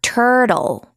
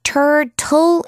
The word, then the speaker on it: turtle: 거북이는 영어로 turtle. Turtle 이렇게 발음해도 되고 turtle 이렇게 발음해도 돼요. T-U-R-T-L-E 해서 turtle.